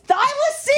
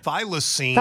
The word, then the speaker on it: thylacine: Yes, thylacine. – Play it again. Thylacine.